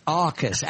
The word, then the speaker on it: AUKUS: I was mispronouncing it for some time, using the OW for AUKUS. And Scott corrected me. It's AUKUS, as in Australia.